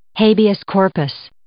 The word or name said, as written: habeas corpus